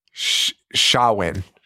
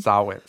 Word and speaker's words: Samhain: Well, how do you say it? – Shawin. – Shawin? – Shawin? – Shawing? – No, it's Shawin, I think. – Shawin? – Sawin. Sawin, I think.